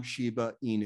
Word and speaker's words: Shiba Inu: They will come on to educate Sam more on Shiba Inu.